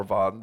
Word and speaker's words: Vav: Okay, the Wah can be pronounced Wav or Vav.